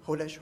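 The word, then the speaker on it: kodesh (קודש): The Hebrew term kodesh, and if I spell it in English, it would be something like C-H-O-D-E-S-H. The C-H is pronounced with a guttural. I heard a comedian one time say that to pronounce it properly, you needed to sound like you had swallowed a fishbone, and you were trying to get it out of your throat.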